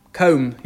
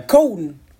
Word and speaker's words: comb: Comb, yeah. – Cone. – Yeah. – But how do you spell it? Cone or pone? – C-O-M-B.